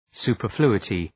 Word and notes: superfluity: ,su:pər’flu:ətı

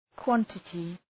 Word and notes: quantity: ‘kwɒntətı

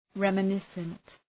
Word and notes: reminiscent: {,remə’nısənt}

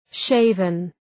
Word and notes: shaven: ‘ʃeıvən

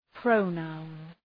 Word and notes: pronoun: ‘prəʋ,naʋn

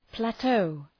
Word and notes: plateau: ‘plætəʋ

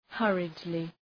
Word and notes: hurriedly: ‘hɜ:rıdlı; ‘hʌrıdlı

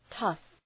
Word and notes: tough: tʌf

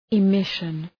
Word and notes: emission: ı’mıʃən

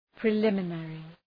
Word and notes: preliminary: prı’lımə,nerı

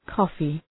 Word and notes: coffee: ‘kɔ:fı, ‘kɒfı

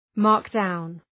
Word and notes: markdown: ‘mɑ:rkdaʋn

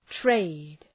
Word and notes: trade: treıd

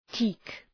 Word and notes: teak: ti:k

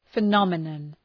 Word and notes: phenomenon: fı’nɒmə,nɒn

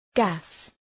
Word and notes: gas: gæs

